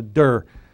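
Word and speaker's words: de (δέ): used to call it der. It's not, there's no R on it. It's just D-E, it's a de, not a der.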